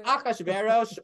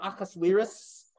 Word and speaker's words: Ahasuerus: Like it's Ahasuerus or Ahasuerus. You could also say the king guy.